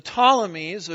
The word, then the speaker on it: Ptolemies: The two most important would be the Ptolemies. There I go again. The P is silent, isn't it? The Ptolemies of Egypt and the Seleucid dynasty of Syria up north.